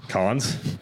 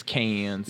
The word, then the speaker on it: Cannes: Cons? That's a Cannes? – It's con. – Con. – I think the French pronunciation is con, but the American pronunciation is cans.